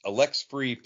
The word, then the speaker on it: Alexfri: Uh, it's Alexfri Planez.